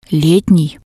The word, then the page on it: letnii: Russian